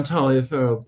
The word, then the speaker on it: Taliaferro: To begin with, my last name is often pronounced Taliaferro, just as I'm sorry. – No, that's perfect. And especially when I'm in Europe or Latin America, almost anywhere, I'm Taliaferro. But in the United States, it's typically Tolliver.